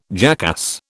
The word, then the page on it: jackass: British English